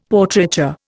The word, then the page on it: Portraiture: Indian